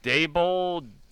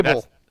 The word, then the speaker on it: Daboll: So the pronunciation is lowercase d, lowercase e, capital I, lowercase b, upside down e and l. – So like, okay, Daboll. – That's helpful. – Daboll.